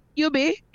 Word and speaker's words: EUBI: How you pronounce the name EUBI? – Yubi, I love call it. It just Yubi. – sound good. Yubi.